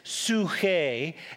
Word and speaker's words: psuche: Well, first of all, in the Greek, the word for life is psuche, and we, if you transliterate it, meaning if you take the words and you make an English pronunciation, it's psyche, which we get the word psychology from.